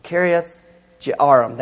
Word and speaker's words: Kiriath Jearim: I can't say that word. It's hard for me to say. Jearim. Kiriath Jearim. That's how you say it.